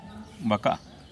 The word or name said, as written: bakaa